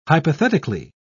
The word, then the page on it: hypothetically: British English